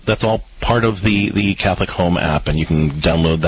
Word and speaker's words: Catholic HOM: It's pronounced Catholic Home, but it's spelled H-O-M.